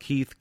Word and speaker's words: Keith: My name is spelled Keith, K-E-I-T-H, Perron, which is P-E-R-R-O-N.